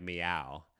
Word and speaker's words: Miao: no, it's spelled MIAO, but they keep saying it like meow.